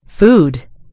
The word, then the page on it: food: American English